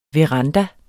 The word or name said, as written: veranda